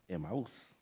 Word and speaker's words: Emaús: Algunos dicen Emaús, pero en realidad es Emaús. La pronunciación en el español, Emaús.